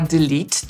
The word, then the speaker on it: delete: Jeżeli macie problem z zapamiętaniem poprawnej wymowy słowa delete, to możecie sobie je skojarzyć z innym słowem, które też kończy się w pisowni na lete i też wymawiamy tę końcówkę jako lead i właśnie tę sylabę akcentujemy, czyli complete, a więc pełny albo uzupełniać.